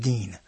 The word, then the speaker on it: Din: D-I-N. – I know it. – Pronounce it Dean.